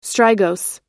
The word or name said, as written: strigose